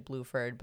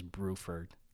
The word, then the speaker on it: Bruford: So I just figured like his name is actually Bluford, but obviously in Japanese voice acting, they pronounce it Bruford. – No, it is actually, his last name is Bruford.